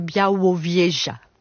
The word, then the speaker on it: Białowieża: forêt de Białowieża, je sais pas si je le prononce bien. – Alors à la française oui, à la polonaise c'est Białowieża.